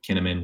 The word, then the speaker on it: Kinnaman: Sorry, I say Schinnaman because I have a Swedish friend who told me once that his last name, because he's half Swedish, would be pronounced Schinnaman. So now I always want to say Schinnaman whenever I see his name, but Kinnaman, whatever.